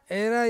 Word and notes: rai: Ansus

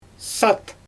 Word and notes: sat: Xyzyl

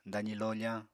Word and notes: dazi lofañ: apatani

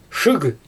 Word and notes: ʃɯɣ: Xyzyl

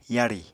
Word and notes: yarì: apatani

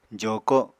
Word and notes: joko7: apatani

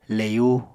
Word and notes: leyu: Apatani

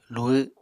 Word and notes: luhw: Apatani

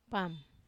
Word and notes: pam: wamesa

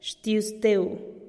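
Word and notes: xtiuzteu: Teotitlán del Valle Zapotec

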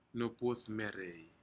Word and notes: nupudmerei: Aneityum

